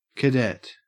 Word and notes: cadet: Australian English

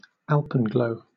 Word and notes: alpenglow: British English

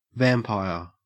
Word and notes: vampire: Australian English

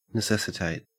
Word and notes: necessitate: Australian English

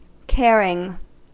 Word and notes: caring: American English

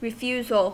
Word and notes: refusal: American English

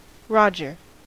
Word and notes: roger: American English

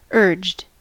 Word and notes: urged: American English

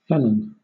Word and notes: felon: British English

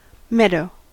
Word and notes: meadow: American English

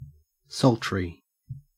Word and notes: sultry: Australian English

